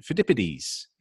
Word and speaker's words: Pheidippides: and you also mentioned Pheidippides the Greek yes.